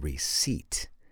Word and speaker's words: receipt: H-E-I-R. What about the silent P? Psychology. Or here's one. How about the word receipt? How do you spell receipt? R-E-C-E-I-P-T. There's the P at the end.